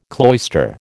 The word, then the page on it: cloister: American English